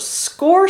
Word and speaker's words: Sqor: Score is spelled S-Q-O-R. Yes. – I thought it was going to be spelled like the score bar. – No, because that actually would have been acceptable to me, but no, it's S-Q-O-R.